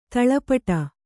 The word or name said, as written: ಧೂಳೀಪಟ